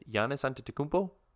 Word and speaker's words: Giannis Antetokounmpo: MVP is Giannis Antetokounmpo.